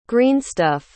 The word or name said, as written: greenstuff